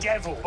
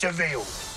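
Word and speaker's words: de Vil: He's spelled devil, but it's pronounced Deville.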